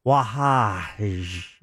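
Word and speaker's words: Wahhaj: Well, his last name is W-A-H-H-A, but it says it's pronounced Wahaj. There's like a J in there.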